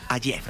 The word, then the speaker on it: Hayez: Francesco Hayez.